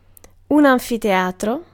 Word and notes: amphitheater: American English; /ˈæmfəˌθiːjətɚ/

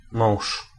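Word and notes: mąż: Polish

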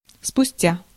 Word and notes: спустя: [spʊˈstʲa]; spustja

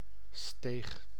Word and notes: aleja: /aˈlɛja/

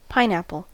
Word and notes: pineapple: /ˈpaɪnæpəl/

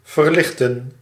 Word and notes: alleviate: American English; /ə.li.vieɪt/

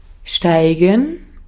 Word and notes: steigen: German

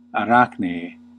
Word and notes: Arachnē: /aˈrakʰ.neː/; Classical

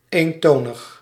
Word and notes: eintönig: /ˈaɪn.ˌtøː.nɪk/

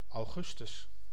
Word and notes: augustus: /ɑu̯.ˈɣʏs.tʏs/

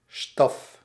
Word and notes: baguette: France; [ba.ɡɛt]

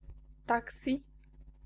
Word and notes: taxi: /ˈtæk.si/

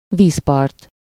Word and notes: shore: /ʃɔː/; /ʃɔɹ/

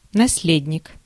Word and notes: наследник: nɐˈslʲedʲnʲɪk

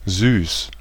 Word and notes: dulcet: /ˈdʌl.sɪt/; /ˈdʌl.sət/

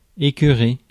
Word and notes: annoy: American English; [ə.ˈnɔɪ]

